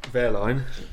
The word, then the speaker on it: Wehrlein: Wehrlein.